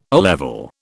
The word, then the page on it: O level: British English